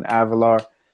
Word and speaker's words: Avelar: On Facebook, my first and last name, Alvin Avalar.